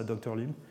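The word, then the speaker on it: DrLIM: Alors, c'est un acronyme, ça veut dire Dimensionality Reduction by Learning and Invariant Mapping, mais on prononce ça Dr. LIM. Ça fait un peu comme Dr. No, ça fait un peu un film de James Bond.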